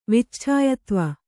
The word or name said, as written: ವಿಚ್ಛಾಯತ್ವ